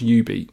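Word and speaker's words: jubeat: It's J-U Beat, and it's pronounced U-Beat.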